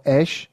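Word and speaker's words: Æ: Je to X, písmeno X, potom AE, což se vyslovuje jako Ash, a potom A12, no.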